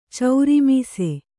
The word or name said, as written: ಚೌರಿಮೀಸೆ